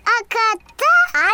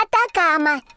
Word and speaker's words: Атакама: Аката? – Атакама.